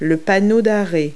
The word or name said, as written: stop sign